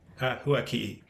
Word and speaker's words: Huaki'i: Huaki. – Huaki? – Yeah, there's like a little glottal stop or like an apostrophe.